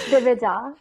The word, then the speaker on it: Divija: It's DIVIJA.